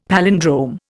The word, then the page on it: palindrome: Indian